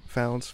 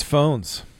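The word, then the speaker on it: Fownes: The Oakmont family. Founds, Fawns. – It's phones.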